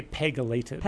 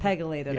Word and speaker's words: PEGylated: Peginated, did you say, drugs? – You could say that. We say PEGylated. – PEGylated, yeah, I said that wrong.